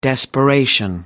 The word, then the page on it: desperation: American English